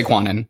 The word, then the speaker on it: Cuanan: So just pretend it's that without the G at the end. But you can say Cuanan. That's what I've been saying my whole life.